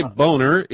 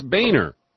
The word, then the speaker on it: Boehner: You don't say Boehner, it's Boehner. – Boehner, okay. Well, it looks like Boehner to me. – Well, they passed up on a Blunt and selected a Boehner.